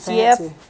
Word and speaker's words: Geoff: How do you pronounce his first name, though? G-E-O. – Jeff. It's Jeff. – It's G-E-O-F-F. – It's Jeff Swaim. – Why is there an O in there? – I don't know. – G-O-F? – It's fancy.